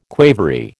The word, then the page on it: quavery: American English